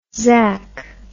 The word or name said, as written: zac